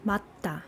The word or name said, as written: majda